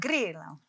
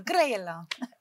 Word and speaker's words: Grillo: Grillo. it's Italian, so Grillo.